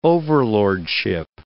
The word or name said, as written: overlordship